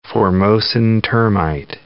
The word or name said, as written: Formosan termite